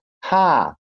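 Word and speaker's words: ha (ห้า): Bruce, the concept though of the five five five and the five five five is basically, guys, for everybody that doesn't know, in Thailand, uh, five is pronounced ha.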